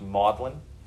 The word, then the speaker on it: Magdalen: So I think at Oxford, the most prestigious schools would be Christ Church, Magdalen, spelled Magdalen, but pronounced Magdalen.